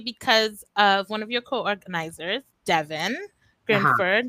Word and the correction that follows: Grinrod: Sorry, I think I pronounced her last name wrong. Grinrod. I apologize, Devin. Yes, Grinrod.